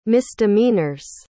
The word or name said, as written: misdemeanors